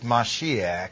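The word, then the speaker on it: Mashiach (מָשִׁיחַ): And the Hebrew word for anointed is Mashiach. We spell it M-E-S-S-I-A-H.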